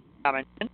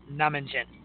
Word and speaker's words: Nominjin: It is N is Nancy, O-M-I-N-J-I, in case you're looking up. Nominjin. – Nominjin. – Kind of like namaste. That's how I'm remembering it.